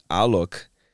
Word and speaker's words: Alok: It's it's Alok. right. Alok. – Alok. – everything is right. Alok. I'm telling you, it's all right. It's because like when I say Alok, people in Brazil, my home, they understand Aloki.